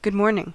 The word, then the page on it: huomenta: /ˈhuɔ.men.tɑ/